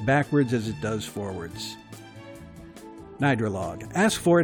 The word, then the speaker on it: Gnidrolog: And I believe the artist is pronounced Nidralog. Now, I'm not exactly sure. I think I'm pretty close on that. It's spelled G-N-I-D-R-O-L-O-G.